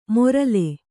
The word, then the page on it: ಮೊರಲೆ: morale